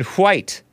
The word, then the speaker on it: white: Mina asked, why put the WH in white, because who, what, when, where, why. That's how it's pronounced.